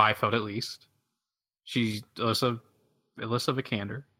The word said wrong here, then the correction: Alicia Vikander: Is that how you pronounce it right? – Alicia Vikander, yeah.